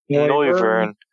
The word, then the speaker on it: Noivern: Noivern.